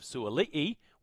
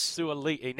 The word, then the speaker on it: Suaalii: which is exactly what Daniel Tupou and Joseph Suaalii, which we now can confirm is the pronunciation, right? – Yes. – Suaalii.